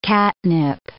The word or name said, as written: catnip